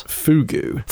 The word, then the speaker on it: Pheugoo: Fugu. – Fugu, sorry, P-H-E-U-G-O-O.